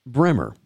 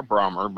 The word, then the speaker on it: Brahma: And Brahma bulls, he says, are not pronounced that way in Georgia. They're pronounced Bremer. – Or Brahmer, but mostly Brahmer.